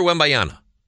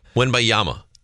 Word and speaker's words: Wembanyama: So, Victor Wembanyama gets us. How do you say the name? Wembanyama.